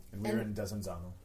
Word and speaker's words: Desenzano: How do you say it again? – Desenzano.